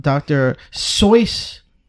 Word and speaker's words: Dr. Seuss: So, on this year's special, we're talking about Dr. Seuss.